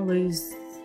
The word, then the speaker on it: Luz: L-U-Z. – Oh, okay. – It's short for like Luciana. Luz.